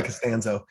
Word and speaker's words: Costanzo: I usually go by Costanzo.